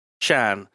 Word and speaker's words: Xan: X-A-N? What's that? How's that pronounced in like Chinese? – Shan. – Hanoi Shan.